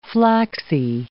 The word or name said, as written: flaxy